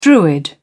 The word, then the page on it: druid: British English